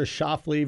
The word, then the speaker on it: Schauffele: You got Xander Schauffele versus J.J.